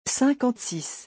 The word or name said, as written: 56